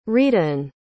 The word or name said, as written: rhydian